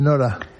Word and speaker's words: Norah: Acaba en H, Norah. – No como la de Ibsen. – ¿No cambia la pronunciación o qué? – No cambia.